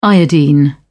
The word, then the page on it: iodine: British English